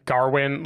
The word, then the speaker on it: Gawain: The final thing I want to say, and I hinted to it before, but I like that not only does everyone pronounce it Garwin, like there's an R sound for sure in the movie.